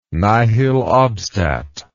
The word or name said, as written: nihil obstat